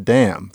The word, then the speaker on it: damn: And I kept waiting for somebody to say damning, but it seemed like they always said damn, frankly, or shit, or something else. And gradually I realized, wait a minute, it's a silent N.